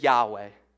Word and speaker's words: Yahweh: And the most commonly accepted pronunciation is Yahweh.